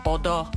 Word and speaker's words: Odo: It's called Yenti, and it came off of a 4-track album called Odo, which means love. And Kwame's shaking his head because I pronounced that right. – Yes, you did.